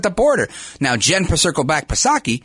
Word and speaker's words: Psaki: And this is why I make fun of Jen Pacircleback Psaki, silent P, of course, because she's always saying something and dancing around the issues.